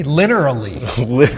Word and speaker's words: linearly: One thing I wanted to touch on that I like to do is pivoting as I go linearly, is that I don't think I said that right. – That sounds good to me, linearly.